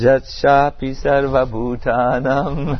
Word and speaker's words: Yashapi Sarvabhutanam: Yashapi Zivri Baninana. In Sanskrit it's Yashapi Sarvabhutanam.